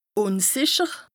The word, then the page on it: ùnsìcher: Bas-Rhin; Strasbourg